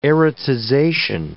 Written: erotization

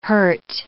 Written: hurt

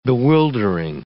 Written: bewildering

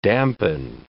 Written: dampen

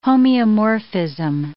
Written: homeomorphism

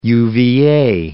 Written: UVA

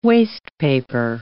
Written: wastepaper

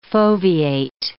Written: foveate

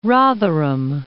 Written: Rotherham

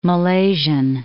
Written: Malaysian

